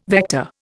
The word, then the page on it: vector: Indian